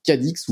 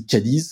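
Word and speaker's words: Cádiz: On va parler de CGP qui est passé, le circuit CGP qui est passé le week-end dernier par Cadix ou Cadiz, ça dépend comment vous prononcez à l'espagnol euh, la ville euh, du sud de l'Espagne.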